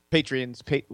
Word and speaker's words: patrons: But before we go, I'd like to thank some of our Patreons. – Patrons. – I always say it wrong, don't I? It's Patreons. – It's Patrons.